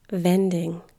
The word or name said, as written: vending